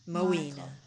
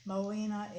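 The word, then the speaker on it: Moina: Moena. – Moena. – Moena is a variation of the French word moine, little sparrow.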